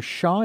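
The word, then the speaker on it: Sha: the series Soul Healing Miracles with Dr. and Master Shaw. Is that how that's pronounced? – That is correct.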